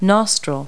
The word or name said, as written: nostril